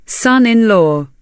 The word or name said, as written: son-in-law